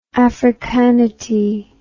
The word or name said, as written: africanity